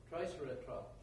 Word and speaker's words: Triceratops: Triceratops. Is that right? Am I saying that right? Yeah, thank you. Say that one more time. – Triceratops.